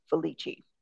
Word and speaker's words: Felice: It's spelled Felice, but it's pronounced Felici.